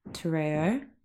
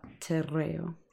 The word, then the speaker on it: te reo: And it's, I feel like for me, even just like, I was very ignorant about a lot of this stuff about te reo. – Yeah. Good. – Including how to say it. – Well, yeah, you're supposed to roll your R's, but it is hard. – Te reo.